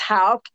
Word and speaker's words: Haugk: Here's another, and you're going to have to help me pronounce that first word, HAUGK, Spiritual Gifts Inventory. – Okay, it's Hauk, HAUGK.